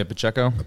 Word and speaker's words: Pacheco: I'll be taking Emmanuel Pacho. How do you say his last name? – Isaiah Pacheco.